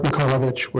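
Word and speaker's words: Mikolavich: Mark Mikolovich with Waterleaf Architecture. Last name is spelled M-I-K-O-L. A-V as in Victor, I-C-H, and we're at 419 Southwest 11th Avenue in Portland, 97205.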